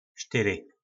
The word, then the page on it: Styry: Po našimu